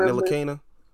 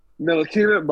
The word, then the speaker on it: Ntilikina: I can never say his name. Nitalika or however the fuck. – Oh, Frank Ntilikina. – Ntilikina, bro.